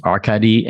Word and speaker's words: Arkady: Today it's Hard to Be a God by the Strugatsky brothers, Arkady and Boris.